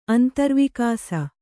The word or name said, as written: ಅಂತರ್ವಿಕಾಸ